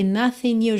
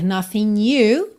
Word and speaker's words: nothing: E aqui nothing you juntou no N, já que o G não é pronunciado. Poderia ser nothing you, como se fosse um NH em português, né? Nothing you. Mas também é muito comum juntar pelo N, nothing you.